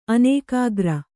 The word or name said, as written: ಅನೇಕಾಗ್ರ